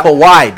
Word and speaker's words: Kawhi: Kiwi. – You know LeBron. You know all the syllables in LeBron name, but you gonna mispronounce my man's Kawhi. It's Kawhi, B.